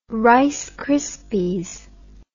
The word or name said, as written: rice krispies